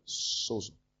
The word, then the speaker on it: sozo: Sozo. It's pronounced sozo, like S-O-D-Z-O, but it's spelled S-O-Z-O.